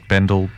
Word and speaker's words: Bendel: So my surname is spelt Bendel, B-E-N-D-E-L.